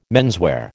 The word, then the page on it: menswear: American English